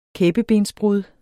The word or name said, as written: kæbebensbrud